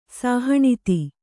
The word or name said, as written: ಸಾಹಣಿತಿ